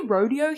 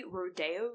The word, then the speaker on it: Rodeo: We would just say rodeo here, but apparently rodeo is the pronunciation, which was at Ballet Theatre.